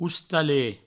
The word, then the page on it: ostalet: oustalet